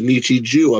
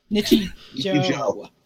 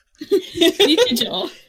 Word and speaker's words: Nichijou: Otherwise pronounced Nichiju, apparently. – Nichiju, right. – Nichijou. – Is that how it's pronounced? – J-O-U. – I've only ever heard it pronounced Nichiju. – Nichijou.